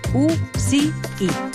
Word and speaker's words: UCI: En el cas d'UCI, cal tenir en compte que la C s'ha de pronunciar amb so de S, és a dir, U-C-I, i no amb el so castellà.